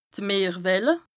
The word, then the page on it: d' Meerwelle: Bas-Rhin; Strasbourg